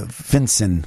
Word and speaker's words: FinCEN: What about this FINSA thing you've been telling? Is it FINSA? Am I saying that right? – Yeah, FinCEN, which FinCEN.